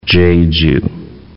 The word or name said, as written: Cheju